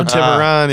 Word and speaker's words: Tiburon: I'm going to pronounce this wrong too, but Tiburon? – Oh, Tiburon, yeah.